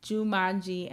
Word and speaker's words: Joomanji: It is J-O-O-M-A-N-J-I, Jumanji, and the song is called Where Are You?